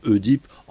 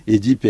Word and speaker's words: Œdipe: Alors, il faut dire Édipe, hein. La plupart du temps, les gens disent Œdipe. En principe, il faut prononcer Édipe.